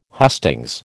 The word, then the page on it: hustings: American English